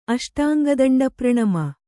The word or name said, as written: ಅಷ್ಟಾಂಗದಂಡಪ್ರಣಮ